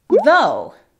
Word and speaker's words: though: Luego tenemos though en IPA. Y tú lo puedes escribir though. Ya saben, tenemos esa TH con voz y sin voz.